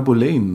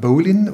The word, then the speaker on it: Boleyn: so wird sie aus B-O-L E-Y-N, Boleyn. – Aus meinem Anne letzten Abenteuerfilm hieß die noch Anna Boleyn, ne? Die wird in England verschieden ausgesprochen. Okay. Das ist jetzt nicht irgendwie dahergeholt von mir. Man kann sagen Boleyn oder Boleyn. Ähm, da sind sich die Engländer selber manchmal nicht einig oder haben nicht eine Aussprache.